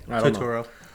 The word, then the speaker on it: Turturro: The one that's on this site is John Turturro. – Yep. – Did I say that right? – Yeah. – Turturro.